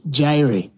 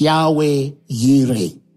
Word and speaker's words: Yahweh Yireh: We want to use the original pronunciation. It's Yahweh Yireh. Y-I-R-E.